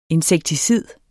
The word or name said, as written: INSEKTICID